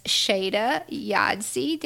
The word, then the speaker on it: Shayda Yazdi: And this is Miss Shayda Yazdi. Did I say it right? – Perfect.